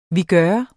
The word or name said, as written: vigør